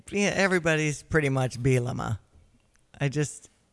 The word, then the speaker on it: Bielema: Is it Bielema? That's the proper way to say it, isn't it, Brett? – It's pretty close. It's Bielema. The second he's a little silent, though. Bielema.